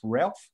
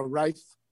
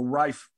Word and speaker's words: Ralph: How do you say, is it Ralph Fiennes? Is it pronounced Ralph? – Yeah, Rafe. – Okay, yeah, well, Rafe Fiennes is in it.